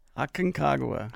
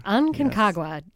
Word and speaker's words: Aconcagua: Later this year or into early next year, you're planning to summit, I'm going to try to say this right, Aconcagua in Argentina? – Aconcagua. – Aconcagua, yes.